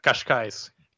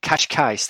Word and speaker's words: Cascais: Is it Cascais? Is that correct? Uh, Cascais. – Kashkais. There we go.